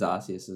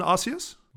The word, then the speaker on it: Arceus: And Pokemon Legends Arceus as well. – Well, sorry, what? And Pokemon? – Legends Arceus. – Oh, sorry, Legends colon Arceus? – Well, technically, I think it's pronounced Arceus now.